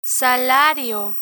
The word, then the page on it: salario: spanish